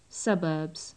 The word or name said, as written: suburbs